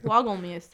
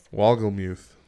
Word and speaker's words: Wolgemuth: Wolgemuth. – Wolgemuth.